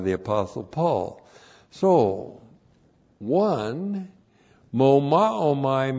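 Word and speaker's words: Mo Ma O Mai (μωμάομαι): M long O M A O M A I. Mo Ma O Mai.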